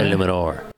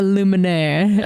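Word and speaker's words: Eluemunor: Can you say his name one more time? – Jermaine Illuminor. – Illuminor. – Yes, not Illuminaire. I remember that's how you were saying it on the Unsportsmanlike Conduct podcast.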